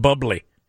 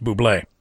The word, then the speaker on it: Bublé: It's a commercial for this business that John Hamm has kidnapped Michael Bublé into being a part of, and it's Ham and Bubbly. Even though, you know, it's pronounced Bublé.